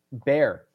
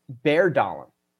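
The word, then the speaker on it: Bjørn Dæhlie: So I incorrectly pronounced Bjorn Dahlin. That B-J-O-R-N is pronounced bear, apparently. So Ole Einar Bear Dahlin, he's your Norwegian stud. So thank you for the correction on that.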